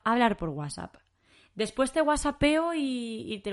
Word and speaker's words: WhatsApp: En español decimos WhatsApp, ¿vale? WhatsApp. ¿Por qué? Pues porque es nuestra pronunciación, es como mejor nos viene decirlo y es así como nosotros lo hacemos.